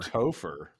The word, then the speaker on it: Topher: Y'all know that one of the top 10 baby names of 1978 was Topher. – Topher? – Topher. – Yes. – How do you spell that? – T-O-P-H-E-R, like gopher with a T.